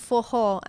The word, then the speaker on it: forró: And actually, a cool fact about it, it's called forró and it's spelled F-O-R-R-O because their R's, when it's a double R, it's a ho sound with a H.